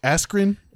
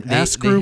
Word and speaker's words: Askren: He fucking pronounces Mexican's name with better fucking rolling R's than a fucking Mexican, and he can't pronounce Askren. Askrew.